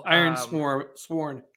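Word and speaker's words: Ironsworn: Iron Sworn. – Iron Sworn. Okay, cool. – Sworn. – Sworn. – Sworn. Sorry. Like sorry. To swear an oath. – I mispronounced it. Iron Sworn.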